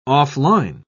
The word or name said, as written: off-line